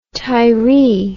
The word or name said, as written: Tiree